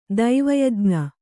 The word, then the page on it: ದೇವಯಜ್ಞ: dēva yajña